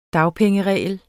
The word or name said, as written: dagpengeregel